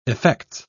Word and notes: effects: British English

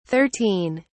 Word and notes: 13: British English

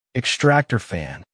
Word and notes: extractor fan: American English

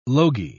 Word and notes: logy: British English

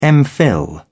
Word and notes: mphil: British English

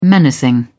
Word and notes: menacing: British English

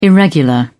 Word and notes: irregular: British English

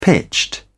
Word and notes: pitched: British English

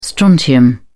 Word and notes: strontium: British English

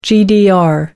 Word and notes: gdr: American English

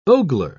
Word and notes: ogler: British English

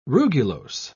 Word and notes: rugulose: British English

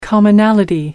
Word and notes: commonality: American English

